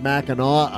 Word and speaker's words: Mackinac: Well, that's the way it's spelled. The locals pronounce it Mackinac Island, but they just haven't figured out how to spell it right.